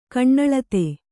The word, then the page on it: ಕಣ್ಣಳತೆ: kaṇṇaḷate